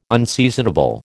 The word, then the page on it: unseasonable: American English